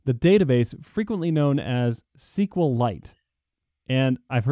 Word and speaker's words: SQLite: So it rhymes with like stalactite. And it's SQLite.